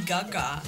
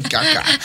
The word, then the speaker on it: Gaga: Lady Gaga. – Caca, igual que yo. No, Gaga.